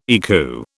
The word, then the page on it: ECU: British English